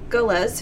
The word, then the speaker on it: Gullah: Louisiana may be famous for its Creole culture, but South Carolina is known for the gullahs. I hope I said that right. I should have looked up gullah. – Gullah? Okay, yeah. The gullahs.